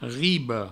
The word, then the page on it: riba: ribo; Provençal